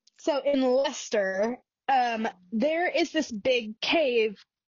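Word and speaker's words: Leicester: So in Dane Hills near, I think it's pronounced Lester.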